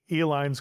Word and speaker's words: Eline: Pabst went into a processed cheese product, and the Elines created the Eline, spelled phonetically E-L-I-N-E, candy company.